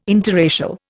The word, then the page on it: interracial: Indian